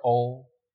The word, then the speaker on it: Om: So this mantra, Om, is pronounced in one breath.